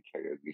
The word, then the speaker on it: karaoke: And then they go to karaoke, and I wrote down how they said karaoke. She said. You said karaoke. – Yeah. – Okay. Yeah. Well, that's because how you pronounce it in Japanese.